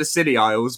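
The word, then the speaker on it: Scilly Isles: So I just took the Sealy Isles, but spelt it phonetically so that it fit. So Sealy Isle.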